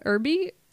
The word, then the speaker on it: Erbe: First off, okay, 1999, Kevin Bacon, Catherine Erby, did I say her name? E-R-B-E. – Sounds right.